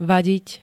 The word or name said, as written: vadiť